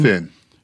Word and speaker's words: Fionn: Finn.